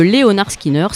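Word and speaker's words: Lynyrd Skynyrd: Et donc prononcé avec l'accent du sud, le fameux Leonard Skinner s'entend Leonard Skinner.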